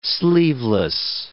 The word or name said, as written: sleeveless